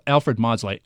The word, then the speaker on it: Maudslay: Yeah, Alfred Maudslay.